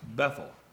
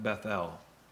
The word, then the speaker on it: Bethel: I guess I should have actually pronounced that Bethel, because El is the word of God, of Jehovah God in the Bible.